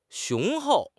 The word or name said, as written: 雄厚